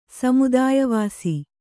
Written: ಸಮುದಾಯವಾಸಿ

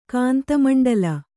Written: ಕಾಂತಮಂಡಲ

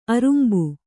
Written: ಆರಂಬ

